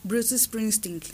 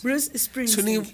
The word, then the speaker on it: Bruce Springsteen: Bruce Springsteen. – ¿Yo qué dije? – No sé, no tengo idea, no te entendí. Bruce, ¿qué? ¿Cómo te atreves? Es un inglés. – Bruce Springsteen.